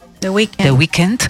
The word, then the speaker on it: The Weeknd: Productor nominado al Grammy, colaborador de superestrellas mundiales como The Week. – The Weeknd,